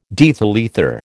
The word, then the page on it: diethyl ether: American English